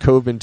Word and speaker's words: Coven: Oh, maybe that's, so he pronounces it Coven too, and makes fun of it when questioned. Like, No, that would rhyme with oven. Oh, maybe that's a scene in the movie. He's questioned like, no, that would rhyme with oven. But yeah, that's actually how it's pronounced.